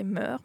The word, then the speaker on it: meurs: Meurs aussi, avant on disait meurs, maintenant on prononce le S à la fin.